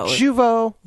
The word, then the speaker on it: Jeuveau: And how do you think you pronounce this? – Jeuveau. – Is it Jeuveau? – Jeuveau. Jeuveau. Very French.